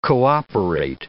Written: cooperate

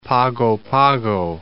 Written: Pago Pago

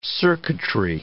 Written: circuitry

